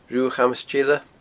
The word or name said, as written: Rubha Chamas Teile